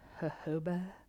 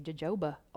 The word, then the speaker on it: jojoba: Or I might mix like an almond oil or a jojoba. I feel so goofy saying that. Like you want to say jojoba oil, but I think the true mature fancy pronunciation is jojoba.